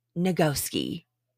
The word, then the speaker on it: Nagoski: I finally got my book, Burnout, The Secret of Unlocking the Stress Cycle by Emily and Amelia Nagasaki. Oh my God, I've been saying it wrong the whole time, you guys. It's Nagoski.